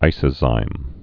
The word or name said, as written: isozymes'